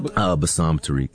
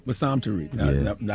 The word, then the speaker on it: Bassam Tariq: How do you pronounce the director's name? – Bassam Tariq. – Bassam Tariq.